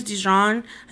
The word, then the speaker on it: Dijon: There's this guy, his name is Dijon. I didn't tell you pronounce it. It's like the mustard.